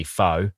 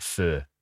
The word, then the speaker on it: pho: And while we're down here too, it's clearly pho, not fur.